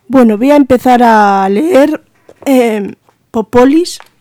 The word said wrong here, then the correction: Polis: No sé si ahora lo he dicho bien. Y bueno, Polis, y yo con Popolis, qué manía.